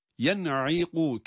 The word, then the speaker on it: ينعق: Yen'iqu kelimesinde bir izhar var. Yen'i. Bakınız, telaffuzu biraz zordur. Yani nunun cezimli halinden ayn harfine geçiş belki biraz yorabilir ama yine sırası geleni oku mantığımızla bunu da çözüyoruz Allah'ın izniyle.